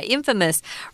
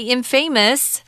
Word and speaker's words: infamous: Yeah, infamous. Remember, this is pronounced differently. Don't say infamous. That's wrong. Infamous.